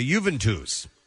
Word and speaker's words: Juventus: It's the President Steve Show is brought to you by Juventus. It is written phonetically for me. U, the word U, V-I-N-T-O-O-S-E. – Okay. – It's Juventus.